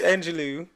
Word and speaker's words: Angelou: She didn't even know which way to pronounce. It's Angelou.